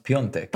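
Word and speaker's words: Piątek: It's spelled Piatek, but it's pronounced Piontek.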